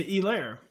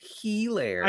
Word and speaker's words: Helaire: The H is silent. – Yeah. You pronounced the H, by the way. – No, I know. I say Helaire. – No, you said Helaire.